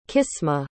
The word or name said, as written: qismah